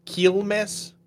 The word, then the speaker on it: Quilmes: The I would be more of an E sound, Quilmes.